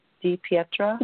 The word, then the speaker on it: DiPietra: DiPietra? – Yeah.